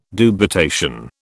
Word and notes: dubitation: British English

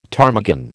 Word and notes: ptarmigan: American English

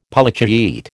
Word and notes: Polychaete: American English

